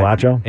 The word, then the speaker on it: Acho: Well, the thing that Acho said was, am I saying that right? – Manuel Acho? – Yeah, okay.